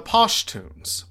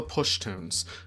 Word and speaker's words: Pashtuns: The Pashtuns, often pronounced the Pushtuns, are the dominant ethnic group in Afghanistan, numbering about 40% of the population.